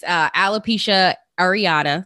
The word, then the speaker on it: alopecia areata: It's alopecia areata.